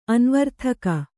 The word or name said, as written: ಅನ್ವರ್ಥಕ